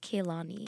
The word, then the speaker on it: Kehlani: I think it's just Kehlani. – Kehlani. – Yeah. – I think I'm pronouncing the H, which is like a problem.